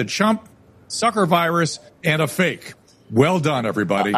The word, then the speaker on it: PHEIC: The acronym is PHEIC, and it is pronounced, in their words, it is pronounced fake.